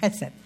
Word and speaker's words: hesed: En hebreo quiere decir nosotros diríamos hesed, pero como no pronunciábamos la H, hesed de Dios.